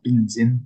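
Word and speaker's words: Bình Định: Well, I think it's actually pronounced Binh Dinh, but I say Binh Dinh.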